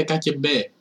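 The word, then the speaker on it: ΚΑΚΕΜΠΕ (КГБ): Νομίζω, νομίζω ε, προφέρεται ΚΑΚΕΜΠΕ, η μυστική υπηρεσία της KGB.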